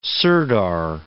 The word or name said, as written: sirdar